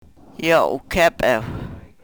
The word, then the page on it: yo u-kepew: Yurok